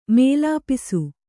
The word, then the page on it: ಮೇಲಾಪಿಸು: mēlāpisu